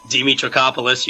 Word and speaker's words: Dimitrakopoulos: Hello, I'm Chris Dimitrakopoulos.